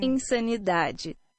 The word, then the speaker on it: Insanidade: Insan- Insanidade. Insanidade. That is what the the name uh, better pronunciation into Portuguese is.